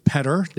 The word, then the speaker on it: Petr: Dave Petr, now that I've said it correctly. – Yeah, we always say it's like better, but with a P.